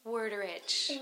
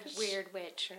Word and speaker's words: Werderitsch: Werderitsch. – Werderitsch. – Or Weird Witch or Weird Witch. – Something like that.